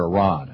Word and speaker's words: Iran: The situation in Iran, or Iran, I guess is the correct pronunciation,